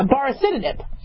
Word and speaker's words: baricitinib: Yet, the FDA, their headline is, we're FDA approving not only remdesivir, right, But baricitinib, am I pronouncing that right? Yeah, baricitinib. Medication that we use for RA, rheumatoid arthritis, for inflammation.